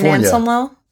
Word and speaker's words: San Anselmo: San Anselmo? – Yeah.